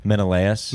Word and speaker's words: Menelaus: Menelaus.